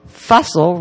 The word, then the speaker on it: Fussell: I called her Betty Fussell, and in fact, it is Fussell rhyming with Russell.